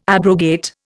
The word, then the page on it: abrogate: Indian